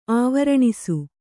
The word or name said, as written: ಆವರಣಿಸು